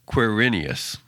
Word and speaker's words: Quirinius: And by the way, it is pronounced Quirinius.